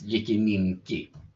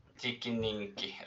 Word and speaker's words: Jikininki: Jag vill jag bara säga att den, den heter faktiskt Jikininki. Jikininki. Ja, kan, du kan ju ta alla uttal du. Ja. – Det, det, är ett tyst än det sista.